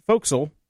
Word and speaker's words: forecastle: Forecastle is pronounced fo'c'sle.